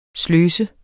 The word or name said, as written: sløset